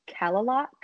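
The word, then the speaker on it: Kalaloch: But you turn into this, I think it's pronounced Kalalok campground,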